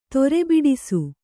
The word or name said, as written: ತೊರೆಬಿಡಿಸು